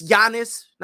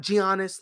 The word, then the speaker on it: Giannis: By the way, it's Giannis, not Giannis. Let's understand how to say the man's name.